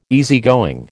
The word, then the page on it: easygoing: American English